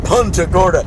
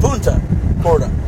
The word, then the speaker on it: Punta Gorda: Because I said Punta Gorda. – Wow. – Rather than Punta Gorda. – They booed. – Yeah. They wanted to be the very English, you know what I mean? – Anglo-stilo. – That's the Anglo pronunciation.